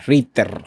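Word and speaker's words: Rytr: esta inteligencia artificial se llama Writer, pero no, no como se escucha, que tú dirías, ah, escritor. No, se escribe R-Y-T-R. R-I-T-R. R-I-T-R. Ritter, Ritter, algo así.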